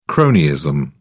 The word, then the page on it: cronyism: British English